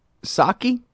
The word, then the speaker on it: Psaki: State Department spokeswoman Jen Psaki, silent P, right?